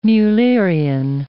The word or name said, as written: Mullerian